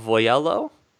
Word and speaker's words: Voiello: Uh, okay, so the the plot basically is that Cardinal Voiello, I believe yep. is how you pronounce